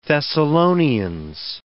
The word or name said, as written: Thessalonians